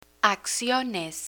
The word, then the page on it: acciones: Spanish